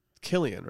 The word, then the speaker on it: Cillian: Killian, right? Hard C? – It looks like it should be Cillian, but it's Killian.